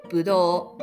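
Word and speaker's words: budō (ぶどう): Grapes are pronounced budō in Japanese.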